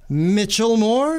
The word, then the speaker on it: Michelmore: We're very, very pleased to have Jess Michelmore.